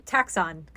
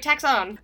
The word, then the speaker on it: Taxon: Taxon controllers. – Okay, same. – What? – It's like Exxon Mobil. – Yeah, exactly. I was like, all right, Taxon.